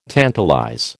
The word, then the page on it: tantalize: American English